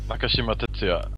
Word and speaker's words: Nakashima Tetsuya: Nakashima Tetsuya.